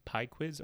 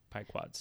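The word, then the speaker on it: Pequod's: So it's either pie quiz or pie quads. – Pequods? – I'm not even close.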